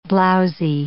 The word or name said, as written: blowzy